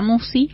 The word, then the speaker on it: Musci: Y en la semana lo subimos al canal de YouTube que lleva mi nombre, Marisa Musi. – ¿Cómo se escribe Musi? – M-U-S-C-I latina.